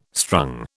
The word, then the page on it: strung: British English